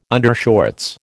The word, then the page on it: undershorts: American English